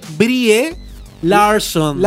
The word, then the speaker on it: Brie Larson: Este interludio, este interludio, 12, aparecieron las primeras imágenes oficiales de Brian Larson como Capitana Marvel. – Ay, mi hijita. – Tenemos imágenes. gusta? Perdón la exclamación, pero gusta? sí. sí. Sí. Ella se escribe Brie Larson.